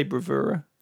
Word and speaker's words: bravura: And this great kind of I can never say bravura. Is that how you pronounce it? – Yeah, that is how you say it.